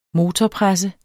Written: motorpresse

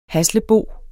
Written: haslebo

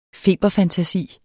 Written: FEBERFANTASIERNE

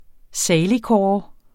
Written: saligkåre

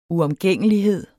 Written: uomgængelighed

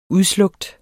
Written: udslukt

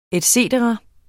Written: et cetera

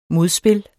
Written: modspil

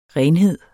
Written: renhed

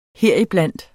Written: HERIBLANDT

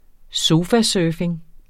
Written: sofasurfing

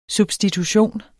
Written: substitution

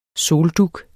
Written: soldug

